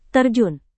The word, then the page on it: terjun: indonesian